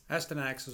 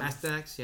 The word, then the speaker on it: Astyanax: Astyanax is what I call him. – Astyanax, yeah.